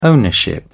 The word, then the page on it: ownership: British English